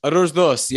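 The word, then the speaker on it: Arroz doce: It's Owl's Dulce. That's how you say it in Portuguese. – Arroz Dos. Yeah. – There we go.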